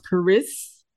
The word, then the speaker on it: Karis: So bear with me, you guys, as I make sure that I pronounce Karis. Did I say it right again? – So it's Karis, like Paris, but like so your emphasis is in the first part, Karis.